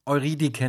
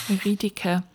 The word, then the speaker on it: Eurydike: Eurydice? – Also ich glaube, ganz korrekt, wenn jetzt hier Leute zuhören, die Latein hatten, die würden sagen, nein, Eurydike natürlich, spricht man das aus. – Ja, Eurydike.